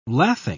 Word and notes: lathing: British English

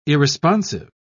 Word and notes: irresponsive: British English

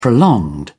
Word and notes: prolonged: British English

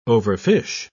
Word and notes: overfish: British English

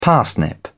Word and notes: parsnip: British English

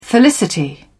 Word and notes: felicity: British English

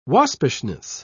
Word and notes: waspishness: British English

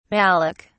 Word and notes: malik: British English

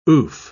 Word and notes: oof: British English

